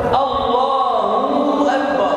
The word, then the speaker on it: الله أكبر: والصواب ان الهاء ليس بعدها ما؟ فكأنه أتى بحرف حروف المد وهو حرف الواو، فيقول الله أكبر، الهاء مضمومة، تنطق الهاء مضمومة، فيقول الله أكبر، الله أكبر.